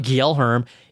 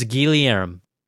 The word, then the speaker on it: Guillaume: People said, hey, it's not Guillaume, it's Guillaume. Guillaume, I got you.